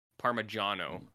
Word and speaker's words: Parmigiano: Parmesan, Parmigiano, I believe is the correct pronunciation.